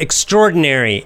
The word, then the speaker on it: extraordinary: Can you pronounce the word extraordinary? Extraordinary. That's the American pronunciation. Extraordinary. There's kind of strored. That middle part gets put together.